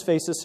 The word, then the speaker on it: PHACES: Okay, so this is PHACES syndrome. Uh, it's comprised of um, uh, a word spelled phonetically as face.